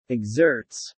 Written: exerts

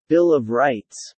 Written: Bill of Rights

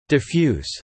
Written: Diffuse

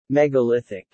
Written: megalithic

